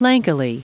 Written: lankily

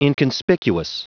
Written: inconspicuous

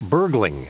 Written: burgling

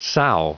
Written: sow

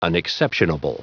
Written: unexceptionable

